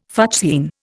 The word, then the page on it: fuchsine: Indian